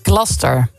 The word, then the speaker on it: Gloucester: De Engelse plaatsnaam Gloucester spreek je uit als... – Gloucester. – Gloucester. – Ja. – Als één vloeiend woord.